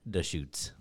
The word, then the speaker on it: Deschutes: Then I Googled the pronunciation. It is, in fact, Deschutes.